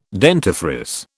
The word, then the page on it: dentifrice: British English